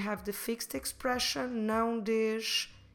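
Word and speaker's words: dês: Não des.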